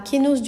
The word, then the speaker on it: kénose: Après, on va aborder le thème de la kénose du Verbe. Alors, le mot kénose veut dire l'anéantissement.